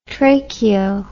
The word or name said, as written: tracheo